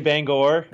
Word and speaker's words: Bangor: Most people around here say Bangor, Bangor. – like Bangor, but it varies a lot.